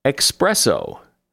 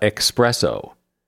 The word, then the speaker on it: espresso: Espresso. Strong coffee in a tiny cup has no X in it, either in writing or pronunciation. It is espresso, not expresso.